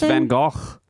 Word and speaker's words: Van Gogh: It's pronounced Van Gogh.